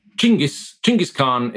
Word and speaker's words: Chinggis Khan: I think if you wonder, you get Chinggis Khan is how most scholars say it properly, but I'm a pragmatist in this regard, rather than strictly politically sensitive and correct.